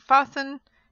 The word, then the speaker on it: fasten: but something like fasten, you don't pronounce it.